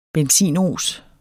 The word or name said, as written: benzinos